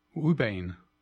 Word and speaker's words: ouabain: It's spelled as O-U-A-B-A-I-N. – Ubane.